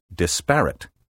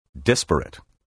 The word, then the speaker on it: disparate: Occasionally, you will hear educated people pronounce the word disparate, with the accent on the second syllable. This relatively recent variant has made its way into the dictionaries and is now standard. Careful speakers, however, use the traditional and preferred pronunciation, which places the stress on the first syllable, disparate.